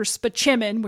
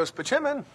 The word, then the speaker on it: Spaceman: But for the rest of the series, they use Dr. Spichemin, which is how you pronounce it, although it's spelled Spaceman, whenever any character needs to consult with a doctor. – This is Dr. Leo Spichemin.